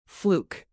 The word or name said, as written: fluke